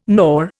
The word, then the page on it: nor: Indian